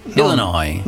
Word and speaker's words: Illinois: Ecco, ripetilo bene il primo, che l'Illinois non si legge la S finale, tutti sbagliano, invece si dice Illinois. – Illinois. – Non Illinois.